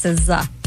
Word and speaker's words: SZA: So it's not S-Z-A. – It's S-Z-A. – but you don't pronounce it that way. No, it's SZA.